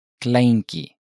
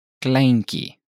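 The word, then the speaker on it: kleintjie: Note the pronunciation of the ending. Could you please repeat that? – Ki. – Now, listen to how the same word is pronounced in Cape Afrikaans. – Kleinkie. – The Cape Afrikaans pronunciation is, once again, closer to the Dutch pronunciation.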